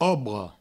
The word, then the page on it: obra: obro